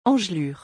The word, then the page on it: engelures: French